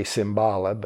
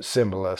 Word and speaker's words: Cymbala: and his name is Jim Cymbala, and uh, some say Cymbala, but he pronounces it Cymbala, so I'll follow his lead.